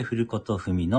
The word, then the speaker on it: 古事記: 今回も、古事記と書いて古ことふみの音読をさせていただきます。